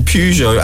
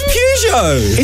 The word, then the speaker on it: Peugeot: How did you say it? – Following similar concerns voiced by the company behind Vauxhall, Peugeot and Citroën. – Peugeot! – It's Peugeot! – It's Peugeot! – It's Peugeot. Listen, it's always been Peugeot.